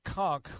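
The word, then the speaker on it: Kuntz: But I'm going to say pick your tight end, but I'll go Zach Conk, or Conke, as Drew Doherty would say. He's just trying to throw us off the scent there with the pronunciation incorrectness. It's Zach Conk, the former quarterback from Stephen F.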